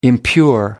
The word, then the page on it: impure: American English